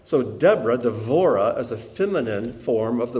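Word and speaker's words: Devorah (דבורה): Devorah is how it's pronounced in Hebrew, Devorah.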